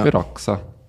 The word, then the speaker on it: Veraxa: Also sehr deutsch ausgesprochen, nehme genau. – ich an. – Genau, ja. Veraxa.